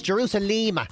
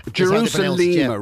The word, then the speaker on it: Jerusalema: This, how do you pronounce it? Is this Jerusalema? – Just asking the young person in the office, and apparently it's Jerusalema. – Jerusalema, right, okay.